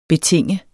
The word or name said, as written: betinge